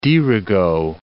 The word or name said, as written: dirigo